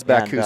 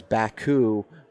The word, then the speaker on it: Bakcou: How's Baku spelled? – B-A-K-C-O-U.